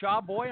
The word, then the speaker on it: Shawboi: S-H-A-W-B-O-I, Shaw Boy.